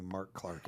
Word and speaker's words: Mark Clark: I don't know why my parents called me Mark Clark. It rhymes.